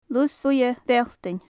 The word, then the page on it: lus buidhe bealltainn: gaelic